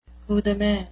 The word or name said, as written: coup de main